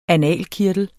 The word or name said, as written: analkirtel